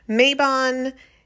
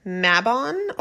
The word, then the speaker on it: Mabon: Maybon is thought to be, or, you know, in my research, how people in the States pronounce it. In other areas, for example, the UK and Ireland and England and Wales, it is oftentimes pronounced Mabon or Mabon, and maybe that's the way I should be pronouncing it.